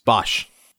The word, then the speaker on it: Bosch: Now, how do you say, is it Bosch? I don't watch this show. Is it Bosch? – It's Bosch. – Bosch. Okay, so it's an Osh. – It's Osh.